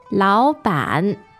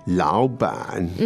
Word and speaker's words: 老板: 老板. 老板. – 老板. The tones there are? – Two-third tones.